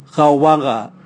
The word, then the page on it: gaowaga: Raga